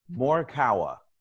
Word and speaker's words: Morikawa: Morikawa.